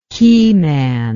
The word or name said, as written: keyman